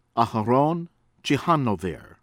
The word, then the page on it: Aaron Ciechanover: from Israel